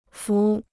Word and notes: 幅: fú

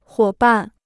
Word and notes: 伙伴: huǒ bàn

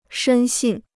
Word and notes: 深信: shēn xìn

